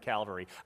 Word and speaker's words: cavalry: No, I said cavalry. I was looking at it and thinking, I got to make sure I say cavalry and not Calvary, and I still said it wrong.